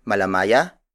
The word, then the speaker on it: malamaya: The last native color is malamaya.